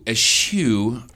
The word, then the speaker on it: eschew: We're going to eschew. – Ooh, I love that word. Spell it. – We're going to It's E-S-C-H-E-W. – E-S-C-H-E-W. – Yeah, it's S. Chew. – Eschew.